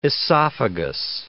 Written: esophagus

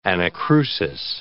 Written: anacrusis

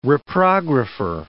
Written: reprographer

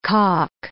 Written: calk

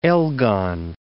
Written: Elgon, Mount

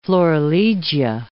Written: florilegia

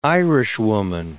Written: Irishwoman

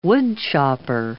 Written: woodchopper

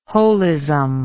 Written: wholism

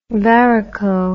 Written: varico

